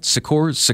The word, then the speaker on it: Secours: Am I saying that right? Secours? – Yeah. It's French.